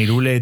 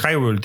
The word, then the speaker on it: Hyrule: Yo, yo la primera vez que jugué a Zelda Breath of the Wild lo jugué en español y cada vez que decían Irule, tío, me ponía una mala hostia. Es Hyrule, tío, de toda la puta vida. O sea, eh, Irule, tío, es que no se dice así, tío. Es que, es que está mal dicho.